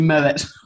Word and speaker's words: Moët: Like an absolute wanker as a child saying, it's actually Moët. The French would pronounce the T there, actually.